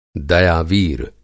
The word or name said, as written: दयावीर